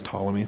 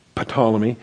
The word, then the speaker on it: Ptolemy: I know it's Ptolemy, it's a silent P, I slip up a lot of times. Ptolemy.